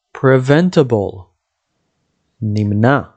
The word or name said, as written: preventable